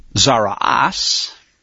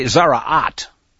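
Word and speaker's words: Tzara'at: By the way, you'll notice that there's a difference here between the Hebrew New York and Sephardic and Ashkenazi pronunciations. It's confusing. I remember being confused for several years. The Bible seems to say Zara'at, and with a tav on the end.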